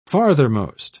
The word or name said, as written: farthermost